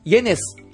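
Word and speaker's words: yenes: イエネス、円はイエネスって言うんですね。 – はい。そうです。 – 円じゃダメなんですか? – イを発音して、うん、イエンって言うんですけれども、はい、ここは複数形になっているのでイエネスになります。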